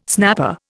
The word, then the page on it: snapper: Indian